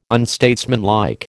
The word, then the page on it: unstatesmanlike: American English